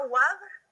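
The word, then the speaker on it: Wavre: Eh, se llama WAB, eh, se escribe Wabre con W, B, em, y está como a 20, 30 minutos de, de Bruselas.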